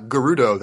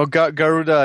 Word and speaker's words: Garuda: It's not Garudo, that's fucking like Ganon. What's the name of it? – You know, Garuda is a...